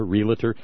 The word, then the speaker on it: realtor: And it's not realtor. That would be R-E-L-A-T-O-R, realtor. No. It's realtor. Because it relates to real property.